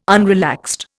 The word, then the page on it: unrelaxed: Indian